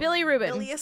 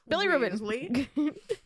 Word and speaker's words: bilirubin: Bilirubin. – Bilirubin.